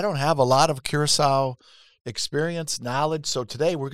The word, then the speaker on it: Curaçao: So it is Curaçao. Curaçao.